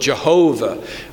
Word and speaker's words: Jehovah: Traditionally, we have called the Lord Jehovah, but the scholars are encouraging us to say that Yahweh is probably more accurate.